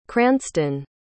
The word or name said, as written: cranstun